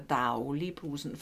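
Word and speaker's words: Dagli'Brugsen: Bei Facebook fragt man uns, wie man den berühmten Daulibrusen ausspricht. Der Daglibruxen ist nämlich der Daulibrusen. Fragt uns nicht, warum man das Dagli-Dauli ausspricht.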